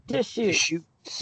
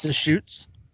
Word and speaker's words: Deschutes: Deschutes. – Deschutes.